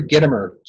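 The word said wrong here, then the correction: Gitomer: Boy, I hope I'm saying it right. It's Gittomer. So his book is called The Little Red Book of Selling.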